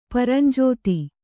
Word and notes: ಪರಂಜೋತಿ: Kannada

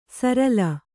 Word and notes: ಸರಲ್: Kannada

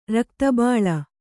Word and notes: ರಕ್ತಬೋಳ: rakta bōḷa

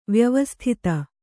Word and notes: ವ್ಯವಸ್ಥಿತ: Kannada

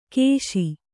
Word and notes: ಕೇಶಿ: kēśi

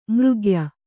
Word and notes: ಮರಗಯ್: Kannada

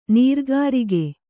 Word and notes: ನರಗುರಿಗ: Kannada

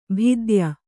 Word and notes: ಭಿದ್ಯ: bhidya

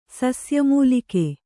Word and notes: ಸಸ್ಯಮೂಲಿಕೆ: sasya mūlike

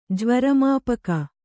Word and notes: ಜ್ವರಮಾಪಕ: jvara māpaka

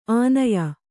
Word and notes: ಆನಯ: ānaya

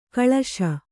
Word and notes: ಕಳಶ: kaḷaśa

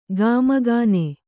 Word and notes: ಕಾಮಗಣ: Kannada

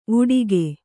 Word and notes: ವಾಟಿಕೆ: vāṭike

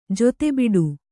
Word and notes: ಜತೆಬಿಡು: jate biḍu

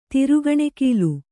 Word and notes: ತೊರೆಕಣಗಿಲೆ: tore kaṇagile

